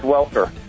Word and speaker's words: Welker: Is it Welker or Walker? – It's Welker.